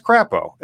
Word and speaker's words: Crapo: the crapo? – Yeah, that was the one being blocked. Technically, he likes to say it's pronounced Crapo, but he's wrong. He's pronouncing his name wrong. – It's Technically, crap.